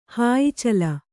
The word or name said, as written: ಹಾಯಿಚಲ